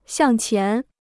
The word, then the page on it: 向前: xiàng qián